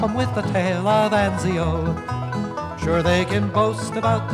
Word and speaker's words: Anzio: But we're starting off with the Ballad of Anzio. Did I pronounce that right, Daffodil? – I would go with that.